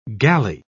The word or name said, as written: galley